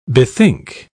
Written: bethink

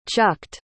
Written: chucked

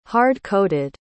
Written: hard-coated

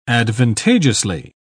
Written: advantageously

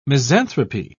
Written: misanthropy

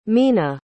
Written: Mena

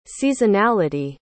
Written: seasonality

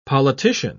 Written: politician

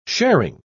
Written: sharing

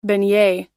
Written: beignet

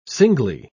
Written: singly

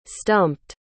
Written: stumped

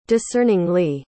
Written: discerningly

